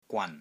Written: GWAN3

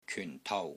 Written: KYUN4 TOU3

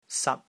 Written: SAT1

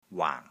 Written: WAANG4

